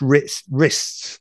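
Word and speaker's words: Rizts: But the support band Ritz, which their drummer Killerton's drummer also played in, Ritz. It's spelt really strange, actually. It's R-I-Z-T-S. So it is pronounced wrists, but I can't get my head round it.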